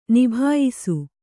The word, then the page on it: ನಿಬಾಯಿಸು: nibāyisu